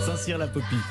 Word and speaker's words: Saint-Cirq-Lapopie: Saint-Cirque-la-Popie. Comment Saint-Cyr la Popie. Oui, c'est ça.